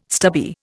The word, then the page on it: stubby: Indian